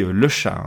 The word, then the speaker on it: Le Chat: Alors, ça se dit le chat, hein, d'après Mistral AI. Ça s'écrit exactement la même chose que le chat.